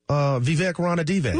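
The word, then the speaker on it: Vivek Ranadivé: And you can also pronounce the owner. – Vivek Rana Divac. – Look at you. That's why I keep you around, man. That was good.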